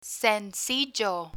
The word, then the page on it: sencillo: spanish